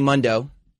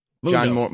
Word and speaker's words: Mundo: Taya and Johnny Mundo. That's how you pronounce it. – And now, the Sam Roberts Wrestling Podcast interview. – Well, welcome Johnny Mundo. – Mundo.